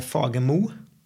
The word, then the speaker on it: Fagermo: Uh, eh, Dag-Erik Oksvold och Anne Fagermo.